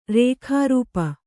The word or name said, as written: ರೇಖಾರೂಪ